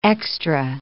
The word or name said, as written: extra